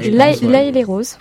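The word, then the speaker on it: L'Haÿ-les-Roses: Voilà, jusqu'au 26 septembre, vous avez aussi l'exposition Shadok à Laïs-les-Roses, je ne sais pas comment on prononce. – Laïs-les-Roses, voilà.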